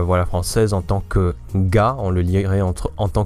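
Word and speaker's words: na (が): Alors, avant de commencer, déjà, j'ai dit ga, c'est un peu la prononciation francophone, mais la plupart du temps, les Japonais disent plutôt na.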